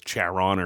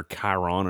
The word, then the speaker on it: Charon: Well, the standard answer for years has been one, and it was Charon or Chiron or however you want to pronounce it.